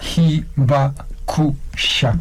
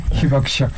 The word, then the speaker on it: Hibakusha: Hibakusha. – Pero ahorita dijo otra vez. ¿Cómo lo dice usted rápido? – Hibakusha.